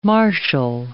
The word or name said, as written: martial